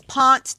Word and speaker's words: Ponce: Ponce, the only word in Atlanta that they really, really, it's hilarious. It's Ponce de Leon. And that's pretty much how they say it.